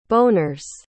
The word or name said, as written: boners